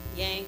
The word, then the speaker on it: Yang: Yang, yes.